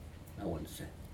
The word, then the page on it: nauunsei: Aneityum